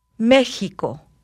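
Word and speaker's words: México: В слове «Мексика» буква «экис» произносится как «х». – Мехико.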